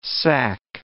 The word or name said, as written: sack